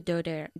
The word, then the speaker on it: daughter: Can your daughter drive? – Can your daughter drive? Por cierto, daughter, nuestra palabra para hija, rima con water. ¿Ok? Mm-hmm. No se dice daughter o daughter. Daughter, como water.